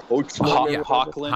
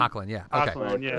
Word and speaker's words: Hoechlin: They don't go the typical, like Tyler Oxtein, I think. I don't even know how to pronounce his name correctly. – Tyler Hoechlin. – Hoechlin.